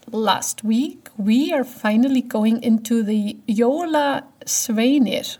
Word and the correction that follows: Jólasveinar: Did I say that right? – Jóla Sveinar. – Sveinar.